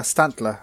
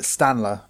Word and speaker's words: Stantler: Stantler. I used to call it Stanler because I didn't see the other T.